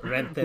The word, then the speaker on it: Renteria: Am I overreacting for wanting Aaron Boone gone when the Chicago White Sox fired Rick Renteria or Renteria? Yeah. I don't know why I said Renteria. I think I was reading it phonetically, and I forgot that I have some Hispanic roots in me. – Renteria. – Rick Renteria. Renteria. – Roll those R's, man.